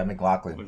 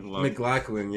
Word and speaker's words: McLaughlin: Shout out to my man, Caleb McLaughlin. – Is that how you say it? – McLaughlin. – McLaughlin, yeah, man.